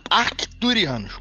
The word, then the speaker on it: Arcturianos: Olha, é Arcturianos com T. – É com C? Esse C é pronunciado? Eu achei que o C não é. – Acho que sim, no MIB era.